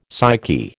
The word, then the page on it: psyche: American English